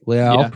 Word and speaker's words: Leal: And I love Jordan Davis, but if Leal, right? Leal, pronounce it like he's a Brazilian.